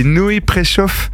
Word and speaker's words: Noé Préchoff: C'est Noé Préchoff. J'ai vu que ça... Alors, c'est compliqué à écrire, mais ça se prononce comme ça.